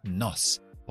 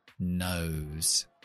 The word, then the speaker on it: nose: Si quito la E sería nos. Poniendo la E hace que la O dice su nombre, nose.